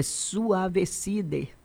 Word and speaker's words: Suavecide: Suavecide, o D é mudo, viu? Não é DE, não. É Suavecide.